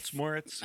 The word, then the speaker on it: Moretz: starring Chloe Grace Moretz. Did I say that right? – Moretz.